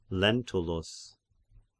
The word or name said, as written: Lentulus